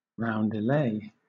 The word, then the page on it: roundelay: British English